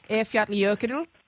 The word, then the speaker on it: Eyjafjallajökull: Eyjafjallajökull, is that correct? – Oh, that's not bad at all. Yes, well done.